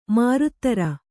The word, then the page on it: ಮರುಧರೆ: marudhare